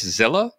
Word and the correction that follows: Zilliqa: Is it just Zilla? The Q always confuses me. – Zillica. – There we go.